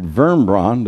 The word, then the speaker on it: Wurmbrand: Richard Wurmbrand, there we got. Pronounce the W with a V.